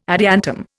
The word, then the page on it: adiantum: Indian